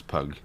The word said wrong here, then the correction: Pugh: Why do you say her name like that? – It's Pew.